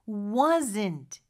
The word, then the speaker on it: wasn't: 네, 요거 이제 발음을 원어민처럼 해보려면 wasn't. 이 부분이 가장 중요할 것 같아요. W를 시작하는 거는 앞에 의가 숨어있다라고 생각하면서 입모양만 그렇게 만들어주면 되고요. T는 있는 듯 없는 듯 느낌만 주면 됩니다.